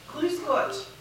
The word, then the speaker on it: Grüß Gott: Grüß Gott. Probably pronounced terribly, right? Now, how does that go? How does that really go? – Grüß Gott.